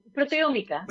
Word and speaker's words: Proteómica: Ella es presidenta del Comité de Empresa de FIBAO y responsable del Servicio de Investigación Proteínica del Instituto Biosanitario de Granada. Más o menos lo he dicho bien. Proteónica. – Es sí.